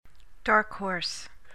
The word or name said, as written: dark horse